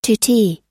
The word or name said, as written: tutee